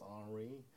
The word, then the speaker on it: Henry: My Galleries and Painters by Daniel Henry, probably pronounced Henri, Conweiler, K-A-H-N-W-I-E-I-L-E-R, with Francis Cremieux.